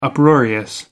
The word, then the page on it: uproarious: American English